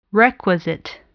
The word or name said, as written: requisite